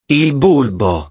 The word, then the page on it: il bulbo: italiano